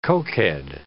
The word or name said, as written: cokehead